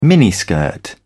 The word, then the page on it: miniskirt: British English